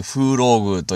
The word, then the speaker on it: 風浪宮: まあ、風の波の宮と書いて風浪宮と読むんですが、